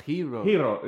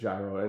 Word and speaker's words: gyro: Although I heard recently that it should be pronounced... – Hiro. The proper Greek pronunciation is Hiro. – Hiro. – But we're in the U.S. and it's gyro, and you get over it, you know.